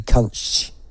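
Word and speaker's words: Kuntzsch: Go to Jodie Kunch.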